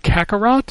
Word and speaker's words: Kakarot: Dragon Ball Z RPG, which has now been renamed to Dragon Ball Z Kakarot. Is that how you say it? – Yeah.